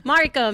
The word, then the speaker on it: Mariko: Mariko. Make it Mariko. Close. That's good.